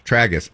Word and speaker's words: tragus: Tragus.